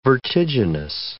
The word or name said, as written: vertiginous